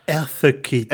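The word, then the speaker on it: Eartha Kitt: En novembre 1950, la chanteuse américaine Erta Kitt, je ne sais pas bien la prononcer parce que j'ai l'impression de dire une saucisse, Erta, est-ce que ça dit Erta? – Erta Kitt.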